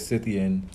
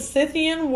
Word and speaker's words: Scythian: I'm gonna say it's Scythian. I feel like the C is silent. – Okay. So, yeah, 3,000-year-old Scythian warriors and their horses.